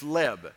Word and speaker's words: leb: And so there's actually a Hebrew word for heart, and it's leb. L-E-B. Very simple. Any of us can remember that and pronounce that, right? Leb.